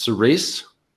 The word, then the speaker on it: Cerise: It's intended to be pronounced Cerise, and that's how Colbert pronounces it when he's introducing it.